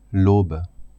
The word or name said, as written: aube